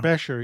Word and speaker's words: Becher: Because I know you wanted to, you said you wanted to cover the, that I think the proper pronunciation is Becher now. – Becher, yes. We from a native speaker.